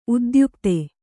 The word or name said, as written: ಉದ್ಯುಕ್ತ